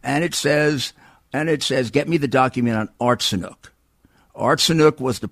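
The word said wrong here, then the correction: Yatsenyuk: Now, maybe Hur doesn't know who Artsenuk is. – Yatsenuk. Or something like that. Sorry, I pronounce it Yatsenuk.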